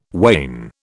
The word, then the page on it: wain: British English